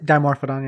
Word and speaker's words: Dimorphodon: Dimorphodon. Is that how I pronounce it? – Dimorphodon, yeah.